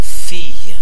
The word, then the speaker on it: fille: J'ai deux filles. Okay, so fille is F-I-L-L-E-S.